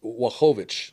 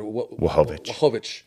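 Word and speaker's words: Błachowicz: If you're Jan, and I think they pronounce his name Wachowicz, looked like with a W. Or Wachowicz.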